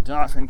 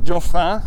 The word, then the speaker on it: Dauphin: Because, of course, my county and Lebanon County and Dauphin County, yeah, it's pronounced Dauphin, even though it's it's the French word for prince, Dauphin. It's actually pronounced Dauphin around here.